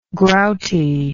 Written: grouty

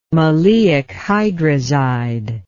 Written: maleic hydrazide

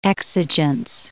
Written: exigence

